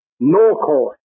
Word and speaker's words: nachash: And it's spelled N-A-C-H-A-S-H. It's pronounced Norcos.